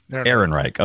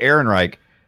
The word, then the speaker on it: Ehrenreich: Ehrenreich. – Okay. Anyway, Ehrenreich.